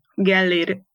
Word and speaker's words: Gellér: My name is Gellér Judit.